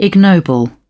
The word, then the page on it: ignoble: British English